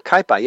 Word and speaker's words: Kaipa: Or I mean, you have the Kaipa. Is that the Capo? Yeah. Is how you say it, or how did you say it originally? – Kaipa. Yeah.